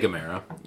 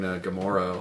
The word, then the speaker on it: Gamera: You can say Gamera. – Yeah. – I think both pronunciations are right. I think last episode I was saying Gamora a whole bunch.